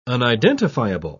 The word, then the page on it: unidentifiable: British English